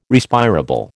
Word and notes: Respirable: American English

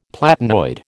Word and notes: platinoid: American English

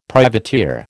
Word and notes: privateer: American English

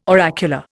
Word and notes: oracular: Indian

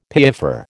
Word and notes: piaffer: American English

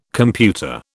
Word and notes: computer: British English

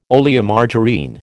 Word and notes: Oleomargarine: American English

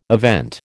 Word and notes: event: American English

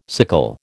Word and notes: sickle: American English

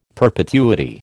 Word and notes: perpetuity: American English

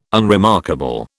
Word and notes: unremarkable: British English

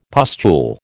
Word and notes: pustule: American English

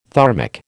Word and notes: thermic: American English

